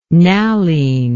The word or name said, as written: Nalline